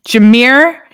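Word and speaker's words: Jahmyr: He was behind Lions running back Jamar Gibbs. Am I saying his name right? – I think it's Jameer. – Jameer.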